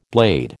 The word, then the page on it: blade: American English